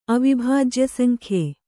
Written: ಅವಿಭಾಜ್ಯಸಂಖ್ಯೆ

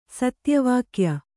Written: ಸತ್ಯವಾಕ್ಯ

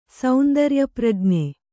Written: ಸೌಂದರ್ಯಪ್ರಜ್ಞೆ

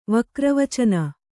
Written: ವಕ್ರವಚನ

